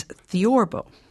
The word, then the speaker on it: theorbo: Is what it looks like, but it actually has a very interesting name, and I want to make sure I pronounce it properly. It's theorbo. – Theorbo, right.